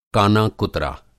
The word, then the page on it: काना-कुतरा: Hindi